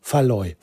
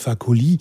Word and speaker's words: Fakoly: Und nicht Faloy. Faloy ist völlig falsch. Was ich immer gesagt habe, warum auch immer, habe ich ihn immer Faloy genannt. Aber er heißt Fakoly.